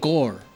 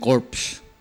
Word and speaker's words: corps: Drums and Bugle Corps. Kaya nag mispronounce siya ng corps, ginawa niyang corpse. Dahil C-O-R-P-S nga spelling nun.